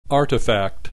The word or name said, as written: artifact